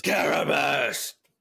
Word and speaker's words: Karabast: That's not how you say it. Try again. – Karabast. – Karabast is how Zeb would say it in the show.